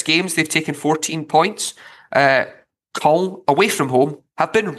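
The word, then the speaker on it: Caen: So it's Valenciennes versus Col. So an A and an E should be. It's like A W. – Yeah. – In Col.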